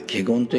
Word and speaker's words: 華厳: ができるっていうのが、まあ、掃除という意味でそのためには 100 選の 3 枚、まあ、いろんなこう悟りの境地を得てですね、それで、でまあ、深く菩薩の法の蔵に入って、これはね、華厳三昧を得るって書いてあるんですよ。華厳三昧というのは、漢字はね、花に厳か、厳格の厳ですね。花に厳かと書いて華厳と読むんですけど、華厳三昧、これどういうものかっていうとですね、まあ一般的に、お経からでの中で説明されてるのは、もうたった一つの誇りの中にも、全宇宙が入っているということです。